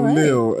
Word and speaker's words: Lael: And then when you called me today, I was like, why do you say Lael? But it's your name. So why do I say But it's your name, so I was like, I don't think you're pronouncing your name right.